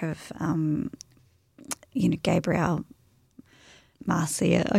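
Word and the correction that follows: García: I can't even say his name. – Garcia, 100 Years of Solitude.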